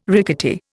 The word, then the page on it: rickety: Indian